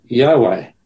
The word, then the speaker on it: Yahweh: Now, I want to ask you, is there any significance about God's name, which in the Hebrew is spelled YHWH, normally pronounced Yahweh, meaning the self-sufficient one or the eternal one?